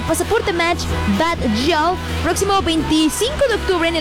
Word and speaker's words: Bad Gyal: Oye, Matcher, prepárate porque Bad Gyal, o como dices, Ingrid, ¿cómo se pronuncia bien? Perfectamente, Bad Gyal. Bajal.